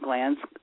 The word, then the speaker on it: glans: It's not like your lymphatic glands, which is spelled G-L-A-N-D-S. G-L-A-N-S is a Latin word, and it means acorn. So the head of the penis is called the glans penis.